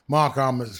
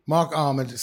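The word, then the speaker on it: Marc Almond: But sure, when I was growing up back in the day, Mark Almond supposedly was having... Mark Almond was having sexual intercourse with a horse that ejaculated inside him. – Oh, right. – Yeah. – Who's he? – Mark Almond, soft cell.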